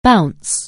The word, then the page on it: bounce: anglais